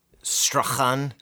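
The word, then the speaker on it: Strachan: Strahan. – I think the CH is silent.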